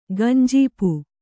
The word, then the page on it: ಗಂಜೀಪು: gañjīpu; Kannada